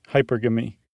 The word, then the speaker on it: hypergamy: Like monogamy. Hypergamy.